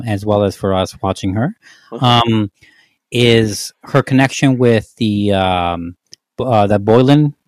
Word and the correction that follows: Boleyn: I forgot her name. – Anne Boleyn. – Yeah. I forgot how to pronounce it. Anne Boleyn.